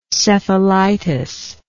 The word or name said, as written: cephalitis